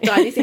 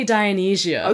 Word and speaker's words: Dionysia: That's how I say it. Dionysia. How do you say it, Allison? – I say City Dionysia.